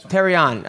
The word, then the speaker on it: Terrion: Terrion, okay.